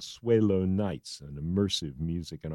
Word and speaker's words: Swaylo: His name is spelled S-W-A-Y-L-O, Swaylo.